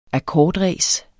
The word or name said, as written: akkordræs